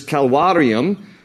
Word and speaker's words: calvarium: And in Latin, the word is, and V's in Latin are pronounced like a W, the word is calvarium, which in English translates as Calvary.